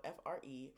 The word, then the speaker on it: Fre: Um, it's spelled F R E, Fre.